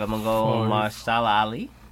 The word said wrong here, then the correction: Mahershala: How you say it again? – I don't know. – Mahershala. – Mahershala. For real? Mahershala.